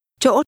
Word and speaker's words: chỗ: Chỗ tôi. Chỗ. Chỗ. – The word for place is pronounced with a tone which begins to rise, but is then sharply cut off.